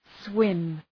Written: swim

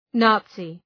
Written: Nazi

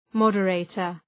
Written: moderator

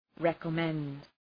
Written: recommend

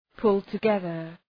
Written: pull together

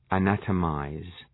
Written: anatomize

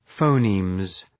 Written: phonemes

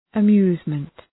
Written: amusement

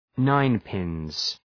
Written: ninepins

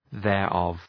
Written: thereof

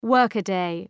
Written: workaday

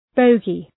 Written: bogey

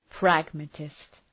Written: pragmatist

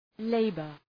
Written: labor

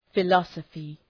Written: philosophy

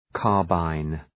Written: carbine

